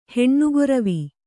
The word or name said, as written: ಹೆಣ್ಣುಗೊರಿವಿ